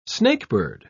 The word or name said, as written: snakebird